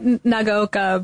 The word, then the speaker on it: Nagaoka: But Nagaoka, but other than that, yes.